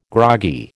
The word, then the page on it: groggy: American English